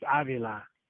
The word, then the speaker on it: Avila: We're gonna call it Avila or Avila, or what are we going with for his name? – Well, in Spanish, it's Avila.